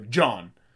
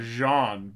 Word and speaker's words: Jean: Not, but they would not say John, he's French, so they would not just go John. – No. – Like, it'd be like Jean, but Jean, like, Gerard. It's spelled J-E-A-N, right.